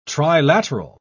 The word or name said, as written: trilateral